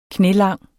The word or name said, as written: knælang